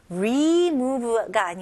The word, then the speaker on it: remove: 여기서도 똑같이 delete처럼 여기서도 remove가 아니고요. re, I 발음 느낌이에요. remove 그리고 V 발음 입술 붙으면 안 됩니다.